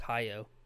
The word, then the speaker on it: Hayao: Legendary director of the Studio Ghibli anime films, Hayao Miyazaki, which I said the first name wrong, and everyone in the comments is coming for me. – I think it's just Hayao.